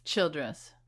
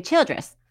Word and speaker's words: Childress: Childress. – Okay, Childress in Texas.